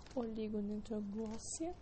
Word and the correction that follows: oligodendroglia: não é oligodendroglia. Oligodendroglia. Estrócitos, células epidimárias e microglia.